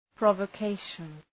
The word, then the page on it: provocation: ,prɒvə’keıʃən